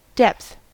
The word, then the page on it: depth: American English